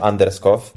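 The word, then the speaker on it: Anderskov: Jakob Anaskow, pisze się Anderskow, przez V na końcu, Anderskow.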